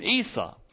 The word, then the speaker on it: Isa: And they call his name Isa, I-S-A, and that's the way the Muslim spelling for Jesus, and his name is spelled different in several languages.